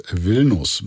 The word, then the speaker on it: Vilnius: Das Buch heißt Vilnus. – Wird aber anders geschrieben. – Vilnius wird es geschrieben, Vilnus ausgesprochen, nach der litauischen Hauptstadt.